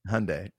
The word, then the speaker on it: Hyundai: For some reason, I want to buy a Hyundai. – Hyundai. I couldn't even say it right at first either. And they were like, think Sunday fun day.